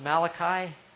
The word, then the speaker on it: Malachi: Malachi is spelled like Malachi. It's pronounced Malachi.